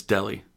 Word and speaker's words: Dele: I said his name right, didn't I? – No, it's Dele.